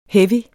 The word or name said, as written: heavy